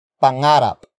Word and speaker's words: pangarap: And that is pangarap. Pangarap. Pangarap. Pangarap is spelled as P-A-N-G-A-R-A-P.